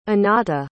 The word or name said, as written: Anata